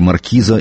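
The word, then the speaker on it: marquis: Сословно Эрл ниже маркиза и выше виконта. Видимо, здесь дело вкуса и звучания. Кстати, французское «маркиз» произносится «марки», так что в русском языке «маркиз» на самом деле женского рода «маркиза».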